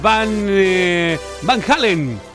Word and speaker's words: Van Halen: Aquí están los hermanos Van Halen.